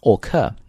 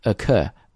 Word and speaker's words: occur: 但注意一下这个词，中国同学经常见到这个 o 字母，包括我在内以前啊，读的时候都读成 occur， no occur、发的是这个音，因为重音是在 cur 上面啊，那么非重读音节呢元音一般会发成央化的这个声音，所以是 where many of the deaths occurred， where many of the deaths occurred 用一个音就好了 occurred。